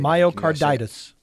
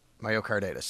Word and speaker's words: myocarditis: Myocarditis. – Myocarditis.